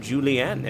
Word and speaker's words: Julien: I feel like that's the Canadian pronunciation of Julien.